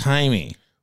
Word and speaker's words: Jaime: Yeah, well, how did he ever come up with Jamie and it's Jaime? – Well, it's because it's spelled J-A-I-M-E, Jaime. Because J in Spanish is a sound. H sound.